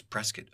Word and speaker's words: Prescott: So that's how the locals say Prescott. You want to say Prescott, but it's Prescott.